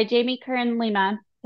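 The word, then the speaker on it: Jamie Kern Lima: Yeah, by Jamie Curran Lima. Did I say that right? Yep.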